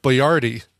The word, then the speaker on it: Boyardee: Because we discovered his name is pronounced Chef Boyardee.